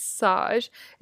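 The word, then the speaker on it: Saje: I don't know how to pronounce it. I think it's Sage. It's S-A-J-E.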